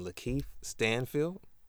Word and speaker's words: Lakeith Stanfield: I think I'm stuck between Lakeith Stanfield.